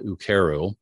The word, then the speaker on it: Ukeru: We introduced something called UKERU, and that's spelled U K E R U.